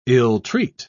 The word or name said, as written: ill-treat